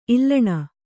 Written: ಈಳನ

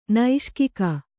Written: ನೈಷ್ಕಿಕ

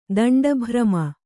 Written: ದಂಡಭ್ರಮ

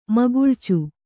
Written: ಮುಗುಳ್ಚು

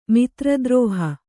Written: ಮಿತ್ರದ್ರೋಹ

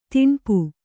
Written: ದಣಪೆ

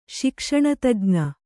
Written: ಶಿಕ್ಷಣತಜ್ಞ

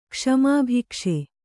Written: ಕ್ಷಮಾಭಿಕ್ಷೆ

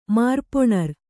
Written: ಮಾರ್ಪೊಣರ್

